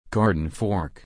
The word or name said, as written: garden fork